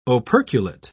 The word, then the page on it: operculate: British English